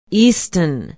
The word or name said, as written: easton